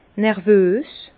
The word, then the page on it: nervös: Bas-Rhin; Herrlisheim